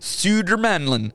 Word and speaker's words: Södermanland: Södermanland.